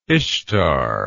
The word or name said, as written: Ishtar